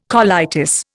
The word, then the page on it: colitis: Indian